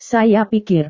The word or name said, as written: saya pikir